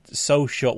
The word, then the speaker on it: Socl: And Microsoft launched their own social network over this last week called Social. Well, it's pronounced Social, but it's spelled S-O-C-L in, once again, like, another example of Microsoft kind of making things a lot more complicated than it needs to be.